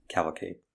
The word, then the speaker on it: Cavalcade: Are we saying that right? Cavalcade? – Cavalcade.